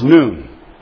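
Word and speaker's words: nun: This is the Arabic letter N. N-U-N, pronounced noon.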